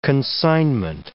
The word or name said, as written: consignment